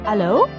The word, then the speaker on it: allô: Like in France, where they say allô, which is the French pronunciation of hello.